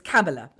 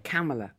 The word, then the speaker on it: Kamala: The wonderful vice president-elect, we were ticked off by Kathy, 52 and 11 months, who I think lives in the States, and apparently it rhymes with Pamela. So it's Kamala, OK? – Kamala.